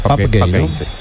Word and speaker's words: Pape Gueye: le pregunté y me dijo la pronunciación correcta, que es Pape Gueye.